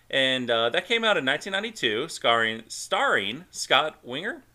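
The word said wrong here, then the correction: Weinger: Is that how you say his name? – Wanger.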